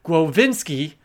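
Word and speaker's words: Głowiński: She is the translator of Mihail, she told me how to say this, and I'm going to get it right, Głowinski,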